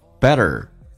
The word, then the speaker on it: better: 然后呢，美式发音当中第一个它有浊化，两个元音中间所加的辅音要被浊化， T 要变成 D 这个发音，而且它需要轻度卷舌，而叫做 better 前重后轻， better, be better.